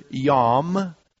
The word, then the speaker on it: yom: Because the Hebrew word that is used here for day is the simple word yom, and it's spelled just the way it sounds as we transliterate it into English. It's Y-O-M.